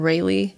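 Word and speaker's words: Rhaelee: You can just type in my first name, Raylee. It's spelt weird, so it's R-H-A-E-L-E-E.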